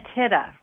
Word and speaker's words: Tida: Painting the Tida, right? Am I saying that right? Tida? Yes, tida, tida, tida.